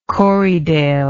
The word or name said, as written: corriedale